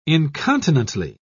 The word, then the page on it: incontinently: British English